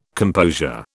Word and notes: composure: British English

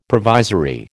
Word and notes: provisory: American English

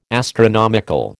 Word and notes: astronomical: American English

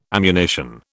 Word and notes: ammunition: British English

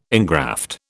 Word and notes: ingraft: British English

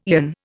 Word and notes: aeon: Indian

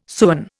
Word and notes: sewen: Indian

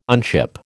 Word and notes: unship: American English

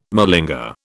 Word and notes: malinger: British English

